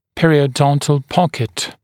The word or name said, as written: periodontal pocket